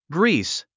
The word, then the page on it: grease: ɡríːs; グリース